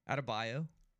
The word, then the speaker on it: Adebayo: How do you say his last name? – Adebayo. – Yeah, they were talking about that on the air, that the play-by-play announcer was calling out of Bayou, like Bayou down there in Louisiana.